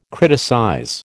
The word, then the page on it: criticize: American English